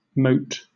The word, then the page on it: moat: British English